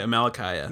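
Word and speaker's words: Amalickiah: So Amalekai, am I saying it right? Amalekiah, right? – Yeah.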